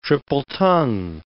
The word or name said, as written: triple-tongue